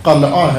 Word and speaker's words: قلعہ: کہ اصل تلفظ قلعہ ہے، لیکن اگر میں قلعہ کہہ دیتا تو سارے سوچتے رہتے کہ پتہ نہیں کس کا نام لے رہے ہیں، قلعہ کیا چیز ہوتی ہے، چونکہ رائج تلفظ زبان میں ہمارے پنجابی تلفظ قلعہ ہو گیا ہے۔